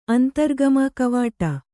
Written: ಅಂತರ್ಗಮ ಕವಾಟ

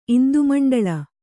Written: ಇಂದುಮಂಡಳ

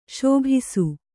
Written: ಶಾಭಾಸ್

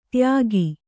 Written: ದಾಯಿಗ

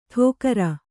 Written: ಠೋಕರ

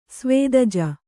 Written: ಸ್ವೇದಜ

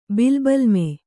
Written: ಬಿಲ್ಬಲ್ಮೆ

